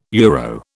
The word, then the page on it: euro: British English